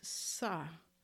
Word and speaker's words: Sau: Y al exhalar el sonido es sa, se escribe SAU, pero pronuncias SA, sa.